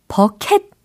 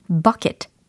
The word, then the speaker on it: bucket: 버켓 아니고 버킷 아니고 약간 버킷 이렇게 하셔야 되는 거예요.